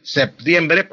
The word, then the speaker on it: septiembre: En la pronunciación lo que pasa es que las personas cuando dicen septiembre, lo pronuncian no pronuncian la P, sino pronuncian es una C. Y dicen el mes de septiembre, así como estoy diciendo, como si estuviera pronunciando de secta, una C. No, es con la P. Para pronunciar la P uno junta los labios.